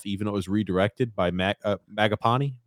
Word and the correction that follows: Mangiapani: What how do you pronounce his name? Mangiapani. Mangiapani. Mangiapani.